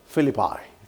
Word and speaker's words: Philippi: How do you pronounce Philippos in English? Philippos? Philippi. Thank you very much.